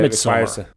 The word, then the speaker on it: Midsommar: You know, whereas something like Midsommar that requires her to, how do you say it, Midsummer? Midsummer? – Uh, Midsummer.